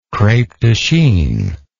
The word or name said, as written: crepe de chine